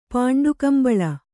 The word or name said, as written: ಪಾಂಡುಕಂಬಳಿ